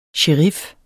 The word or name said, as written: sherif